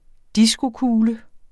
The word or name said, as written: diskokugle